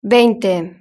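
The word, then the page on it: veinte: Spanish (Spain)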